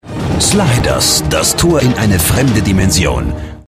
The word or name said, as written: Sliders - Das Tor in eine fremde Dimension